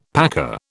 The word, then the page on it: packer: British English